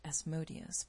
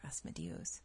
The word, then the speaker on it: Asmodeus: Asmodeus, I've heard it pronounced Asmodeus, but I prefer Asmodeus, appeared as a perfectly normal man, which is common amongst his kind, but he really was a pretty powerful guy.